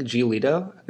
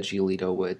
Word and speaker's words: Giolito: At the same time, I think Giolito, that's how you pronounce his last name, right? – I believe so. Okay. That's close enough.